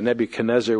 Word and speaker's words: Nebuchadnezzar: but we're also used to the Nebuchadnezzar, which the Hebraized transliteration leads us to.